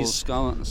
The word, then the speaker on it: Scowen: And we've got the new sign-in, Scowen, is it? I don't know if I pronounced that right. But Joel Scowen.